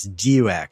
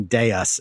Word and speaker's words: Deus: I continuously and repeatedly referred to Deus Ex as Deux instead of saying Deus in Latin.